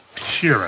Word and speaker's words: Sheeran: Frank Sheeran. Am jag saying that right? Yeah, you said right.